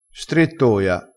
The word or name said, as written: strettoia